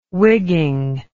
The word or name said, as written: wigging